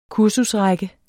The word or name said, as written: kursusrække